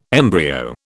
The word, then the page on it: embryo: British English